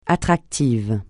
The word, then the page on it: attractif: francais